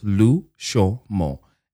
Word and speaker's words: Lushomo: It's literally just three syllables, Lushomo.